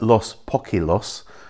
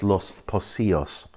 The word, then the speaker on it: Los Pocillos: and the double L becomes a Y. So it looks like Los Poquilos, but it's actually pronounced Los Pocillos.